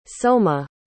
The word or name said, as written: souma